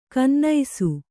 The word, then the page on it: ಗುನ್ನಿಸು: gunnisu